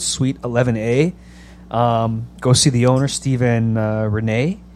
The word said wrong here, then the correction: Reina: Uh, I think I'm saying that right. Maybe it's Reina. Reina. It's Reina. I'm sorry. It is Reina because I remember she said that.